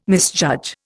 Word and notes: misjudge: Indian

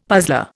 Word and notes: puzzler: Indian

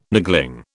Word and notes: niggling: British English

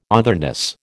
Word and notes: otherness: American English

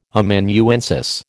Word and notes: Amanuensis: American English

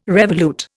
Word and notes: revolute: Indian

